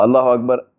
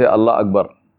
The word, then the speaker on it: Allahu Akbar: Now, Allahu Akbar, including in the takbirat of the salah, many times we hear people, they say Allah Akbar. They don't say Allahu Akbar, they say Allah Akbar. Now, they're not doing it deliberately, but that the takbir is wrong.